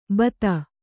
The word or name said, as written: ಭಾತಿ